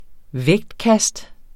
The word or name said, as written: vægtkast